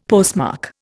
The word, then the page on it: postmark: Indian